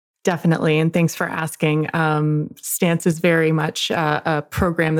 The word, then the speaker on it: STNCE: Am I saying that right, Stance? – Stance, exactly. – STNCE, for those that are listening.